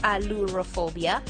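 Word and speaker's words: ailurophobia: And what we are going to look at is the fear of cats, which is called allurophobia, which is spelled as A-I-L-U-R-O then phobia. So it is allurophobia.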